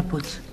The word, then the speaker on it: Puig: Se pronuncia Puig. – Señora, aquí pone Puig y se lee como se escribe. Si quiere que ponga Puig, lo pongo con CH.